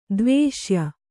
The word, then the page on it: ದ್ವೇಷ್ಯ: kannada